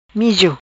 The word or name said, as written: Mijo